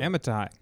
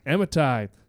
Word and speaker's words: Amittai: Amittai. – Amittai, saying, "Arise, go to Nineveh, the great city, and call out against it, for their evil has come up before me."